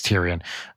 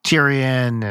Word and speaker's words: Tyrion: And then eventually, I think like Tyrion, or is that how you say his name? Tyrion. – You're asking the wrong gal. – I think it's Tyrion.